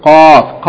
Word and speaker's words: qaf: Remember, kaf should be distinguished from qaf. Qaf is pronounced at the very end of the mouth before the throat, and kaf is just a little bit before.